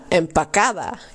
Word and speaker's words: empacada: Want het is natuurlijk queda en pacada.